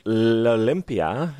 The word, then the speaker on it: L'Olympia: The venue is L'Olympia. Am I saying that right? – Yes, perfect.